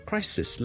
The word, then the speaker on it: crisis: 另外仲有一件事上集冇同大家讲咧，就系 crisis 咧最尾嗰个 s 音咧系 s 嚟嘅，所以个 sis 咧系短嘅，而 crises s e s 咧就会变咗后面最尾嗰个 s 咧系 z 音嚟嘅，所以 crises crises crises。